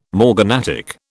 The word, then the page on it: morganatic: British English